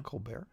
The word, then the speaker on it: Colbert: Colbert. There's no T. We don't. – There is a T. – Well, we don't pronounce the T.